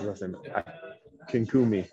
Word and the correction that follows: Akinkumi: Yeah, Daniel Akinkumi. Akinkumi.